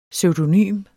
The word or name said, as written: pseudonym